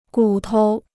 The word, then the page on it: 骨头: gǔ tou